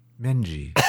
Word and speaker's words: Menzies: I went to, it's spelled Castle Menzies. – Like John Menzies, the old news agents. – But they pronounce it Castle Menji.